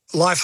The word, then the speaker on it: Lyf: Life Hotel, LYF is how hotel.